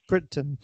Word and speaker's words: Crichton: Written both the screenplay and the novel by Michael Crichton. Is that how you say his name? – Crichton.